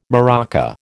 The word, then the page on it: maraca: American English